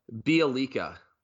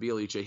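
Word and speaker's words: Bjelica: No, Bielica. – Yeah, Nikolai Bielica or yeah, Bielica. Yeah, yeah, that's how you pronounce it, Bielica.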